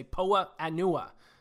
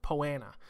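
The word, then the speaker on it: Poa annua: I mean, it always throws me off, because it's like 2 words. It's P-O-A space A-N-N-U-A. So I want to always say Poa Anua, but it's just kind of pronounced Poana.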